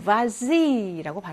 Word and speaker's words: vas-y: 그래서 vas-y라고 발음을 한다라고 했죠.